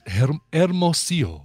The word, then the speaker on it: Hermosillo: Scott, that would be pronounced Hermosillo.